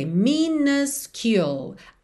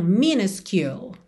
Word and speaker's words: minuscule: Então, a gente vai ter minuscule. A primeira letra U vai ser um a. Já a segunda vai ser you. Então, minuscule. Minuscule.